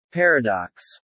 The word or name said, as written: paradox